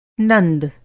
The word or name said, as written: नंद